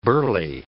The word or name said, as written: burley